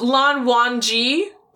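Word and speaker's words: Lan Wangji: Yeah, it's pronounced Lan Wan Ji, but it is spelled like W-A-N-G-J-I.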